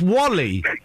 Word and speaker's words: WALL-E: Well, but it's pronounced Wally. Why would you call a film Wall-E? That doesn't sound like a name. You pronounce Wally.